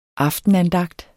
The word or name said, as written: AFTENANDAGT